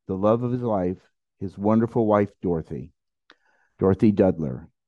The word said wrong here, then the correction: Duder: Deuter. It's like the Big Lebowski.